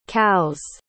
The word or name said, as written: cows